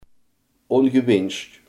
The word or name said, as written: ongewenst